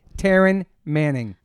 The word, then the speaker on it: Taryn Manning: Please welcome to the podcast, Taryn Manning.